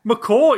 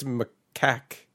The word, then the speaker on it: macaque: So, yeah, it does seem like it's macaque.